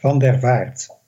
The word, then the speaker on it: van der Vaart: Almost. Wick, Wick van der Vaart. We would call that in Dutch.